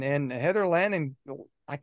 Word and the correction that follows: Langenkamp: Langenkamp. – Langenkamp.